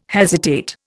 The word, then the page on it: hesitate: Indian